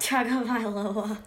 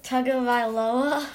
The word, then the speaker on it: Tagovailoa: Tagovailoa. – Okay, say that again. – Tagovailoa.